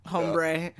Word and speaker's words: hombre: Hombre. – Hombre. It's hombre. H is silent.